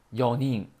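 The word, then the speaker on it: 四人: 然后三个人就是 sanin 啊，四个人是 yonin 啊，yonin。这个四有两个读音，一个是西，一个是用。但是在这种情况下呢，就要读成 yo。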